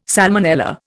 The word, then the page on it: salmonella: Indian